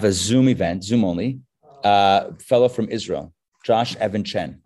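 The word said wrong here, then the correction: Even Chen: Evan Chain, not Chen. I'm pronouncing his name wrong this whole time. He told me his name is Evan Chain.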